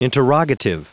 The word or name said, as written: interrogative